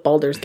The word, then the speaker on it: Baldur: pronunciations in American English are acceptable. – Okay. – The actual pronunciation... Excuse me. I love that that's the word I messed up. Cool. Is Baldur.